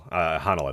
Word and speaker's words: Honnold: Honnold, I believe is how you pronounce it.